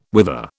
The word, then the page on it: wither: British English